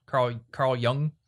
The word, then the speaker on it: Carl Jung: A philosopher, psychologist, Carl Jung.